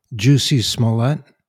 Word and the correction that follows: Jussie Smollett: Oh yeah, Jussie Smollett.